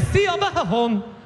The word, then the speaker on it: Siobhan: There's no V in Siobhan. Where does the H go then? It goes after the B, obviously. What are you talking about? B-H is pronounced V. No, it's not, love.